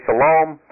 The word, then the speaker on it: Salome: So some would say Salome, but when you actually look at the pronunciation, it should be Salome.